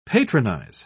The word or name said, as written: patronize